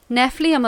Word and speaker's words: Nephilim: Demons are the disembodied spirits of the Nephilim. Nephilim are the crossbreed children.